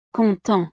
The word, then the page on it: content: frances